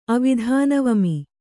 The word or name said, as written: ಅವಿಧಾನವಮಿ